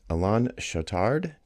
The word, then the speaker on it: Alain Chautard: Today I am joined by guest Alain Chautard. Did I say that right? – Yeah, you say that right.